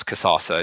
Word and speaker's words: Kazaza: You're both joined by Chief Marketing Officer at Kazaza. Have I said that right? – Pretty close. It's Kazaza. – It's close. You're in the ballpark, right? – Yeah, it's more like a sauce, Kazaza.